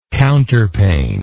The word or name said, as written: counterpaned